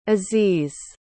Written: aziz

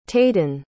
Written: tayden

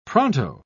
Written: pronto

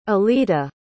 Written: alitta

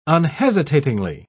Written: unhesitatingly

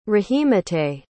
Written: rahimateh

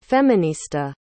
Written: feminista

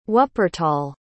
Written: wuppertal

